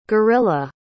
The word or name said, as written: guerrila